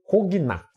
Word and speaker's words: Cognac: Vai vir de uma palavra de origem francesa, então foi abrasileirado como conhaque, já que é mais ou menos assim que se pronuncia em francês. Se escreve co Cognac.